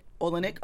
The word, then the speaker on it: Olynyk: Am I just saying that correctly? – Olynyk.